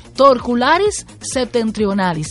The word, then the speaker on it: Torcularis septentrionalis: Torcularis septentrionalis, se lo dije bien, ¿verdad? – Lo dijo, excelente.